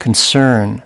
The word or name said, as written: concern